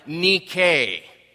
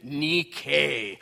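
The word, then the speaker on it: nike (νίκη): Pronounce it, nike. How do you usually pronounce that word? Nike, with the swoosh, right? But that's not where John's thinking about Nike, nike, or anybody in the Greek world.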